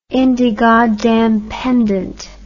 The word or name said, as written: indegoddamnpendent